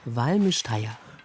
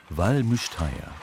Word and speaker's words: Val Müstair: Valmüsteier. – Valmüsteier.